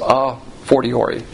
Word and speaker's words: a fortiori: That's a Latin term, a fortiori. F-O-R-I-T-O-R-I. And the preface to that is the letter A, pronounced a fortiori.